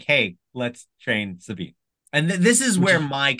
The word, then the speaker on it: Sabine: Rhymes with Shmameen. – Yeah. So, obviously, he's implying that it's Sabine.